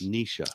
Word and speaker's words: Nisha: she told us it's pronounced Nisha. – Oh, Nisha. Okay, well, that's pretty. – I forgot. I didn't even see the H in there. It's Nisha.